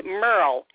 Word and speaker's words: Merle: It's Merle, M-E-R-L-E, and the last name is Exit, E-X-I-T.